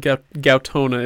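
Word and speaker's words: Gaotona: And in this case, the stamps, I really appreciate the viewpoint of, I believe it's Gaetona. – Gautona is how Brandon pronounces it, Gautona.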